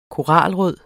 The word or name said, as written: koralrød